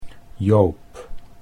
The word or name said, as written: Joop